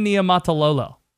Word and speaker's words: Niumatalolo: Ken Niumatalolo.